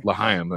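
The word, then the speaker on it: Lahaina: Lahaim, that's how they say it.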